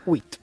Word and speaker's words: huit: Huit.